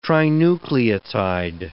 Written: trinucleotide

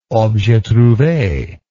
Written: objet trouve

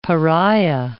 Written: Pariah

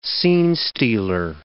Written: scene-stealer

